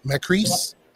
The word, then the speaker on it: Makris: I'm pronouncing the name right, but it says Perry Macris, M-A-K-R-I-S, Macris. – Macris. – It says G-R-G-R-G-R-G-R.